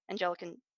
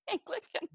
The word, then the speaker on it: Anglican: And like, I have never attended an Anglican, wow, I mispronounced that entirely. Your denomination. Thank you. Thank you, Anglican.